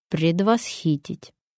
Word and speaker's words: предвосхитить: Неприятность можно предвосхитить или предвосхитить. Ой, это сложно, сейчас скажете вы, и правда сложно, потому что здесь трудное ударение, и многим оно портит слух. Правильно будет предвосхитить.